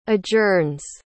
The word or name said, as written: adjourns